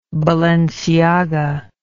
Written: balenciaga

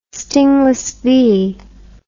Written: stingless bee

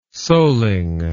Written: soling

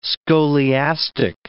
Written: scholiastic

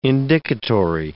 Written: indicatory

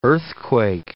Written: earthquake

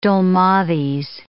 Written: dolmades

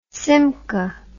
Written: simca

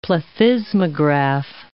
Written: plethysmograph

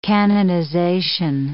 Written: canonization

